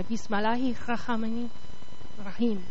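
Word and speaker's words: Bismillahi Rahmani Rahim (بسم الله الرحمن الرحيم): Bismillahi Rahmani Rahmani Rahman. Sorry, I said that wrong, didn't I? Bismillahi Rahmani Rahim. Sorry, thank you, Henry.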